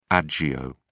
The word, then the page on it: agio: British English